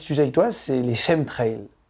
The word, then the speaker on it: chemtrails: Chemtrails.